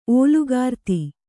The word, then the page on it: ಓಲುಗಾರ್ತಿ: kannada